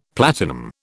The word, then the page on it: platinum: British English